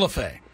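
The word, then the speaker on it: Lefebvre: Joe LaFever. – Joe LaFay. – Oh. – It's pronounced LaFay.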